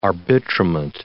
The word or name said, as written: arbitrament